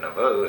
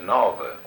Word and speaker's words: Nauvoo: N-A-U. N-A-U-V-O. Nauvoo. – Nauvoo. Uh-huh. – Now, how do you pronounce it, really? – Well, really, correct. It's Nauvoo. It's Nauvoo. The way they call it. Nauvoo. N-A-U-V-O.